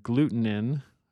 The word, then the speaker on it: glutenin: Gliadins, which is the scientific name for gluten, and then glutenin, that's very confusing, but that's spelled G-L-U-T-E-N-I-N. It's not gluten. It's a different protein called glutenin.